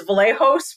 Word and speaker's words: Vlahos: So it's Tracey Lady V, but it is Vlahos for anybody who wants to know.